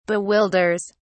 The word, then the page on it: bewilders: British English